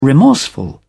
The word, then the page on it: remorseful: British English